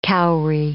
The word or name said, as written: kauri